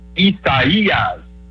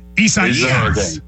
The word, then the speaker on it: Isaias: It's four syllables. The third one, you got to kind of draw that fourth syllable down like Louisville. It's Isaias. Isaias. – Isaias.